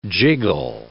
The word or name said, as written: jiggle